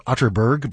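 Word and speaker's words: Atterberg: It's spelled Otterberg, but pronounced Otterberg, just in case you are Googling.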